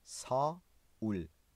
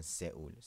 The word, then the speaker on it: 서울: سئول در زبان کره میشه ساول نمیگن سئول سئول تلفظ فارسی